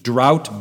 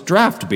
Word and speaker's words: draught: D-R-A-U-G-H-T, drought beer. Of course, it's really not pronounced drought, it's draft beer, isn't it? G-H, draft beer. Cough, C-O-U-G-H. Why isn't cough just spelled C-O-F, cough?